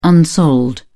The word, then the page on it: unsold: British English